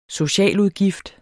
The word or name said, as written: socialudgift